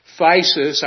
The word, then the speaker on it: phthisis: He may have had a condition known as phthisis, I think is the way we pronounce that, P-H-T-H-I-S-I-S. I think it's pronounced phthisis.